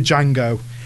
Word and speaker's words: Django: D Django. – He screams D Django, and he just goes, the D is silent, hillbilly, and then shoots him again.